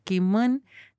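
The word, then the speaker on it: Kimvn: Entonces intento escribir ahora con esa identidad eh, y seguir a Kimun que se pronuncia así, Kimon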